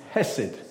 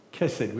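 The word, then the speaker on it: hesed (חסד): It's hesed. Hesed, or sometimes pronounced kesed with a K in front of the H.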